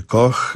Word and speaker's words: Koch: Koch pisze się Koch, Amerykanie wymawiają Koch, to jest nazwisko ojca i syna.